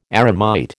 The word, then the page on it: eremite: American English